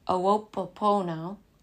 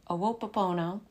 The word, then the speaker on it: Ho'oponopono: I looked up the pronunciation because I've only ever read it, and I feel so badly because it's actually kind of a difficult pronunciation, but I'm going to do my best here. Ho'oponopono, Ho'oponopono, and